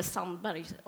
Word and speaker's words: Sandberg: galen feminist som heter Sheryl Sandberg, eller Sandberg om man uttalar på svenska.